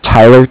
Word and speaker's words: Tyler: First name is Tyler, T-Y-L-E-R, and I'm calling with Cardiff. It's spelled C-A-R-D-I-F-F, as in Frank Frank.